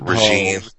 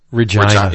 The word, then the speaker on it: Regina: Or is it Regine? – Regina. – It's rhymes with vagina.